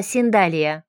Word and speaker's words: Синдалия: «Правильно говорить, не сандалеты, а Синдалия, с ударением на букву А».